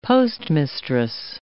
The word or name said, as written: postmistress